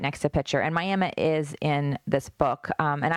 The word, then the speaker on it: Miami: And so there's the biggest town in Ottawa County is Miami, and it's spelled like Miami, Florida, but pronounced Miami, and I'm from that town.